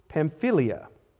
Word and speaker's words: Pamphylia: After going through Pisidia, they came into Pamphylia.